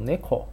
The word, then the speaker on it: 猫: 因为猫在那个日语里面念作 neko，呃，它出处的话是关于 kodomo ga nemasu。 kodomo 是小孩的意思，nemas 就是指睡觉的意思，呃，就意思是猫就叫 neko 的话，